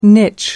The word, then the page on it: niche: American English